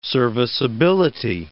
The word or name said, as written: serviceability